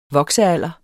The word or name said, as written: voksealder